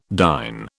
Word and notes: dine: British English

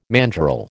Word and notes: mandrel: American English

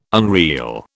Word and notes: unreel: British English

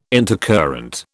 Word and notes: intercurrent: British English

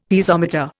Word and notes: Piezometer: Indian

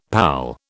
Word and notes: pal: British English

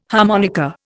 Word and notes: harmonica: Indian

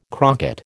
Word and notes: crocket: American English

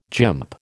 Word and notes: gymp: American English

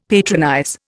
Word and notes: patronize: Indian

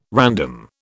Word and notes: random: British English

